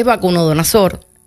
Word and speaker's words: Nabucodonosor: Y me trae también al tema, en Daniel 1, hay una porción de la palabra que dice que el rey Nabucodonosor, me cuesta un poquito trabajo decir ese nombre, creo que lo dije bien, creo que lo dijiste súper.